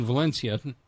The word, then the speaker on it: Valencia: Thank you to David and Janice in Valencia. Did I say that right? – You did.